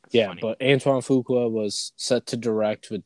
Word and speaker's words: Antoine Fuqua: Antoine Fuqua, the director.